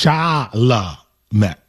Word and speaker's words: Chalamet: You never once managed in that segment to say it correctly. You said sham. You said charm. You said. – It's confusing, though. – Timothy what? – Well, now I know it's Chalamet. I know it's like the whole. – Okay, so yeah, well, that's three syllables, but let's put them all together into a word.